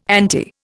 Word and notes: ante: Indian

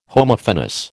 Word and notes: Homophonous: American English

